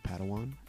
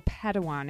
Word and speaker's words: Padawan: think it's pronounced Padawan. – Oh, Padawan, excuse me.